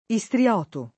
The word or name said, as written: istrioto